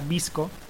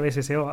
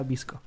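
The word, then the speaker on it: VSCO: Em, hay otra aplicación que es muy famosa que se llama BSCO, que se pronuncia Visco.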